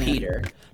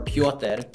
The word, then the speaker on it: Petr: It's spelt P-E-T-R, you say it, Peter. Technically, you could say it, technically it's Petr or Piotr, which is how you would say it. – Piotr. – But I go by Peter, and it's spelled P-E-T-R. – Yeah. – And the reason it's spelled P-E-T-R,